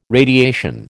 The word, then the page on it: radiation: American English